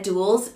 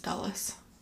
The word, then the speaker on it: Dulles: It's pronounced Dulles. – Your face is Dulles. Sorry. What did I say, Dulles? – Dulles. It's Dulles. – It does say dull.